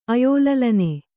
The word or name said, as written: ಅಯೋಲಲನೆ